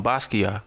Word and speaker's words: Basquiat: It's just say Basqui and then ya. – Ya. – Like, Basquiat.